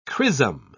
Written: chrisom

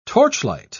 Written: torchlight

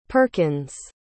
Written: perkins

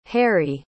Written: harrie

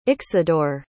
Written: Ixidor

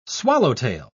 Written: swallowtail

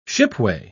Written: shipway

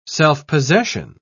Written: self-possession

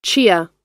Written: chia

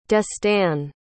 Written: destan

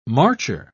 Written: marcher

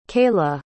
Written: keila

